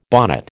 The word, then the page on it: bonnet: American English